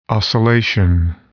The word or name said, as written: oscillation